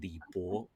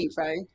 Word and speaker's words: Li Bai: the other being Li Bo or Li Bai, depending on how you pronounce it. – Li Bai.